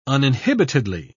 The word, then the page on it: uninhibitedly: British English